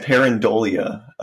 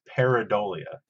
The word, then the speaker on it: pareidolia: I think I pronounce it with an N. I pronounce it parandolia. Uh, And I was like, oh, people are going to correct me. Uh, now people are. I'm going to say peridolia. – Peridolia? Well, I'm not going to f- fix it now because now I'm worried. I'm when I, had to spell it, I, there was no N in, in it.